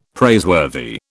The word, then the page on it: praiseworthy: British English